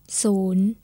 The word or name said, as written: Soon